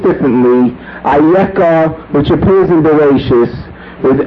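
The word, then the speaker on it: Ayeka (אַיֶּכָּה): The word, as it appears in the text of the Chumash, is spelled as Eicha, Aleph Yud Chaf Hei, but it is pronounced in its context as Ayeka.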